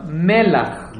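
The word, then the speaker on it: melach (מלח): How do you say salt in Hebrew? – Melech. – No. That's not how you say. – Melech. – No, melach. – Melach. – Melech and melach are two different words.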